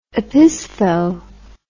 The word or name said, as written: opistho